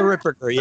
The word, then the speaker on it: Ripperger: Yeah, it's I think it was a YouTube homily talk by Father, I don't even know how to say his last name properly, Ripperger? – Oh, the Ripperger, yeah.